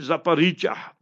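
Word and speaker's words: Zaporizhzhia: And it is in Zaporizhia, if you pronounce the word correctly.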